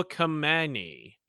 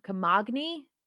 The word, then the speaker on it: Camagni: Jacopo Camagni. – Camagni? – I think the G is silent.